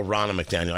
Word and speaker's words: Ronna McDaniel: Although, to hear them angry that Rona McDaniel was hired, well, Ronna McDaniel, I should say her name properly, that Ronna McDaniel was hired.